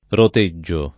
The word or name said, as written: roteggio